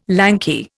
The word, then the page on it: lanky: Indian